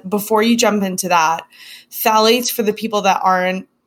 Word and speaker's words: phthalates: So phthalates, while we're on it, those are a big one.